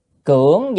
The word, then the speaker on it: cưỡng: Cưỡng danh, chữ này đã phải đọc là âm mà cưỡng chứ không đọc cường được.